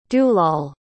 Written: dulal

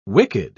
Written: wicked

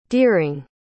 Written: deering

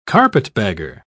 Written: carpetbagger

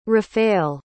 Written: rafale

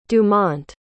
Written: Dumont

